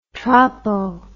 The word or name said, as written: tropo